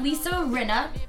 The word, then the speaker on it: Lisa Rinna: So we have Lisa Rinna.